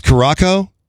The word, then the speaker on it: Curaçao: But I think it's pronounced Curaçao. It's C-U-R-A-C-A-O, the national team.